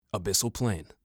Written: Abyssal Plain

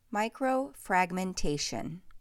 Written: Micro-fragmentation

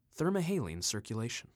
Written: Thermohaline Circulation

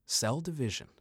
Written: Cell Division

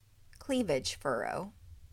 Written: Cleavage furrow

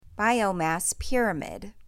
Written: Biomass pyramid

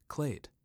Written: Clade

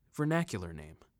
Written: Vernacular Name

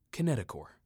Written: Kinetochore

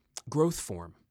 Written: Growth form

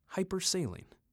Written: Hypersaline

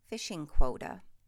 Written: Fishing quota

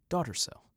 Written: Daughter Cell

